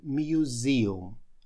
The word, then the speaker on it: museum: Послушайте еще раз произношение. Почему-то это слово многие неверно произносят. Museum. Так оно произносится. Слово reason.